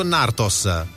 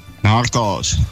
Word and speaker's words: Narcos: Narcos o Nartos? – Narcos. – Come Nartos con la T di Torino, Narcos.